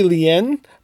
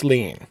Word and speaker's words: Lien: And I'm really curious about Kes, who, let me see if I can pronounce her last name, Jennifer Lean? – Now we're going to get into that. Does Andy know how to pronounce it? I believe you're correct. It might be Lien, but I think it's Lean.